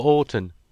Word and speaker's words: Auton: Is he called Orton or Orton? – Orton. – Spell it. – A-U-T-O-N.